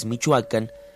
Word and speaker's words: Michoacán: Bueno, pues entonces, para finalizar, recordaremos que Michoacán, cuya verdadera pronunciación es Michoacán, significa lugar que tiene pescados.